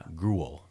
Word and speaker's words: Grewal: Okay, I'm gonna spell this. You tell me how you pronounce. – Okay. – G R E W A L. – G R E W A L. – Yeah. – Gruel. – Gruel Hall.